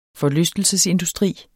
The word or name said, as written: forlystelsesindustri